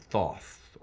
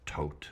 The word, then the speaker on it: Thoth: The Hermetic in the group's name refers to Hermes, the Greek equivalent of the Egyptian god Thoth, or Tot, as occasionally some stickler will tell you, though I suspect that's an imitation of early German archaeologists' pronunciation.